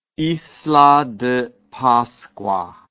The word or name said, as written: isla de pascua